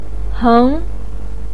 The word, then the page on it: heng2: pinyin